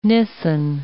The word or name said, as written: Nisan